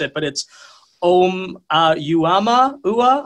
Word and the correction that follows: Oumuamua: Am I saying that at all correctly? I am not. It's probably more like Amu Amua. – Oumuamua.